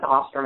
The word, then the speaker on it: Ostrom: Nobody can pronounce Ostrom ever correctly.